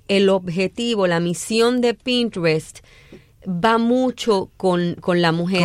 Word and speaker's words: Pinterest: Hay Pinterest, ¿se dice así? – Pinterest.